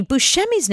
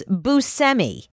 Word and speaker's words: Buscemi: Oh, actually, we've been saying Steve Buscemi's name wrong. – What is it? – He literally just told us recently that it's Buscemi.